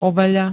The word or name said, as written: overený